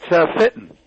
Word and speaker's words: Fitton: It's Fitton.